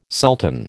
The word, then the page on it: sultan: American English